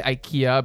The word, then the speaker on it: IKEA: So we looked it up and apparently it's pronounced IKEA.